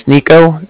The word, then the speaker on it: Niko: And my name's Nico. That's spelled N-I-K-O. And then the Bank of Cardiff is spelled C-A-R. D-I-F-F, as in Frank.